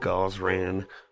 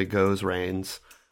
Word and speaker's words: Gozran: Tell us how to say goes rains. – Well, it's not that. I'll tell you that much.